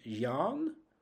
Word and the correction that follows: Jaén: Uh, Jaén. Jaén, of course, it's Jaén.